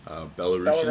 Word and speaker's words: Belarusian: I think that's how you pronounce people from yep. Belarus. Belarusian. – Belarusian, yep. – Belarusian.